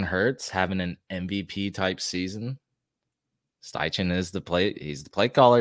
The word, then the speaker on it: Steichen: Shane Steichen. It could be pronounced Stechen, but we're going to go with Steichen. Eagles offensive coordinator for the last 2 years.